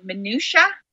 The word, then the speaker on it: minutia: And that is a problem, because when you get down to the, I'm going to say this word wrong, minutia, and you're like, did I say it right? – You did.